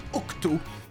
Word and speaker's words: OxT: chanté par Octo. – Ça s'écrit O-X-T.